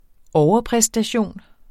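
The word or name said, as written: overpræstation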